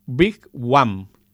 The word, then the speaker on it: Wigwam: tenemos al grupo Big One que se escribe Wigwam, con W las dos.